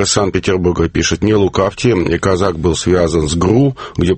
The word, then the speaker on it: Козак: Но поскольку Дмитро Миколаевич, так сказать, чистокровный хохол, то правильно будет произносить в малороссийской транскрипции «Казак».